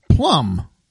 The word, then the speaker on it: plumb: And let's see, plum, but it's spelled P-L-U-M-B. So not the fruit.